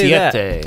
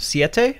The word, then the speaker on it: Siete: How do you say Siete. – That? Siete.